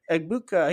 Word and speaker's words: Egbuka: Egbuka, he's a redshirt sophomore.